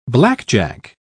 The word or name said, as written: blackjack